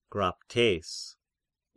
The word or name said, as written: Graptes